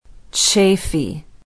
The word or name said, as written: chaffey